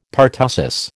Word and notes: pertussis: American English

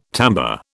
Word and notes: timbre: British English

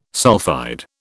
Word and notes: sulphide: British English

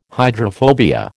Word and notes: hydrophobia: American English

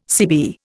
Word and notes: CB: Indian

